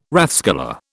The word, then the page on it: rathskeller: British English